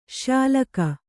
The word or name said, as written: ಶ್ಯಾಲಕ